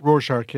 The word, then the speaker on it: Rorschach: He didn't get it, but when he applied, they did the Rorschach, is that how it pronounced it? – Rorschach, yeah.